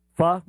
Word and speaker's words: pho: Pho? Like F-A? – P-H-O. It's pronounced okay. Pho.